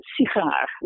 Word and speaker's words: sigaar: Now, you take a word like cigar in English. Now, the first syllable is going to be reduced. It's cigar, right? And you have exactly the same word in Dutch, but it's sigaar, right? So the first syllable is not reduced. It's secondary stress.